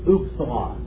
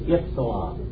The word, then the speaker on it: ipsilon: This word here, they will pronounce it like this, upsilon. All right? But that's not the correct way to pronounce this Greek letter. It is ipsilon. All right? It's an I.